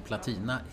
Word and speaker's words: platina: Och det andra är att eftersom platina är ett korrekt uttal också... – Så är det? – Ja, det står ju i ordlistan.